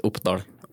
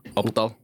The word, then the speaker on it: Oppdal: The name of the town is Oppdal. Oppdal. – Oppdal. Yep. And it's spelled like Oppdahl? – The A-L. Oppdahl. – D-A-L.